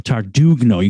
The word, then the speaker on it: Tardugno: She told me Brooke Tardugno. You kind of pronounce the G. – Yeah. – All right. Mm-hmm. It's just, she said it's supposed to be silent, but you can pronounce it if you want.